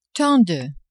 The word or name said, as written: tant de